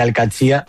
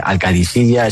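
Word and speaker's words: Al-Qadsiah: Bueno, se suele decir Al-Qadsia, pero también hay gente que dice Al-Qadisiyya. Es que es difícil, ¿eh? Ah, no es fácil, pero Al-Qadsia es lo más fácil.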